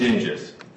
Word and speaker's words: Dinges: Dinges. – Is it D-I-G-I-S? – D-I-N-G-E-S.